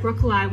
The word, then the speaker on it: broccoli: Yeah, yeah, with a long I, like B-R-O-C-C-O-L-I-E. As opposed to broccoli.